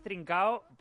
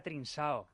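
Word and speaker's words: Trincão: La pronunciación es Trincao, por Dios que sea Trinsao. Porque si es Trincao... – No lleva cedilla. – Da igual, en fútbol y radio se llama Trincao.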